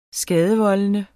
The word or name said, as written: skadevoldende